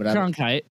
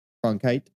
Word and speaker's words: Cronkite: It's, it's, probably pronounced in America. It, might be Cronkite. I don't know how to say his name, it's Cronkite. – Cronkite.